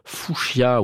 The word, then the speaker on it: fuchsia: fuchsia, oui, ça se prononce comme ça, et derrière jaune.